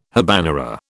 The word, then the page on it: habanera: British English